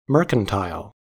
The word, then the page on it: mercantile: American English